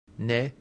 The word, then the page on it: ne: Latin